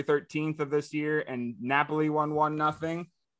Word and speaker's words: Napoli: Is it Napoli? – Yes. – Okay. I got it. – Way to rub it in. I can't pronounce words and you can. Cool. – Napoli is the underdog at +270 and a tie is at +260.